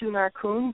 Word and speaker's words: Xunar-kun: Um, it's actually Xunar-kun, but it's like nobody nobody's ever been able to pronounce it.